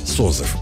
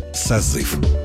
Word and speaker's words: созыв: Еще несколько десятилетий назад ударение в слове «созыв» считалось вариативным. То есть ударным мог быть как второй слог, так и первый. В середине 20 века норма изменилась. Правильным признан единственный вариант произношения «созыв».